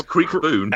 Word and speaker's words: Kriekboon: B-O-O-N. Creek, K-R-I-E-K-B-O-O-N. – Well, this is Creek Boone.